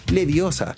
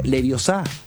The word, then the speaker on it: Leviosa: Porque Harry Potter está cumpliendo 25 años y es Leviosa, no Leviosa.